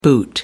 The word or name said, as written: boot